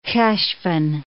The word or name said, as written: Heshvan